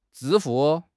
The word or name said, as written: zhi1 fu4